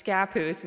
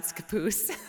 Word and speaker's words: Scappoose: You told me that there is a tiny town with a name that I found so unique and unforgettable called Scappoose. – Yeah, and actually if you say it like that, they'll get mad at you. It's Scappoose, not Scappoose.